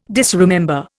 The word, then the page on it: disremember: Indian